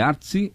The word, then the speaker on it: Artsy: Vai lá no Instagram, Artsy, que é A-R-T-S-Y. Tá? É Artsy, é a pronúncia essa? – Isso, Artsy. – Artsy Poa, tá, que é uma galeria, né?